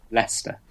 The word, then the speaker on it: Leicester: He is a UK, as in United Kingdom, Space Agency Research Fellow at the University of, how do you say that, Leicester? – Leicester.